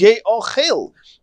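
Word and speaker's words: ye'ochel: The pronunciation, the way you read it, is ye ochel, talking about the Korban Pesach.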